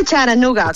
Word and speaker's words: Chattanooga: Se dice Charanooga, con la T como muy suave, sí.